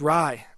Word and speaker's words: Rhye: All right, another artist, help me with the pronunciation here. Rye? – Yes, Rye. – Rye. – R-H-Y-E. Yeah.